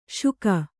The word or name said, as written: ಶೂಕ